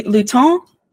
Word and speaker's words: Lutin: L-U-T-I-N. I'm assuming okay. I said that correctly. – Lutin. Oui. – And the Nain Rouge, N-A-I-N.